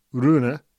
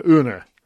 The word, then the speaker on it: Rune: And I thought we'd nailed it, uh, Holger Rune. Um, but it turns out that Holger Rune doesn't pronounce the R, so it's apparently Holger Une, which has really caused me some some problems.